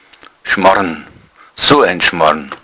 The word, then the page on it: Schmarrn: bayerischen Dialekt